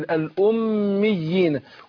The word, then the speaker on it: الأميين: يعني لما مكنتش من الياء لو أنا أقول أميين كده الأمي، يبقى نطقت ياءين، بس أنا شددت الأولانية عشان إيه أمكن للتانية، يبقى أنا إيه ده اسمه تمكين الأميين.